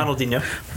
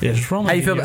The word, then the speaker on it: Ronaldinho: Ronaldinho. Am I saying that right? – Ronaldinho. – Almost. – Almost. Go ahead and say it for me. – Ronaldinho. – Yes, Ronaldinho.